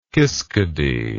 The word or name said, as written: kiskadee